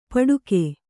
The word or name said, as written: ಪಡುಕೆ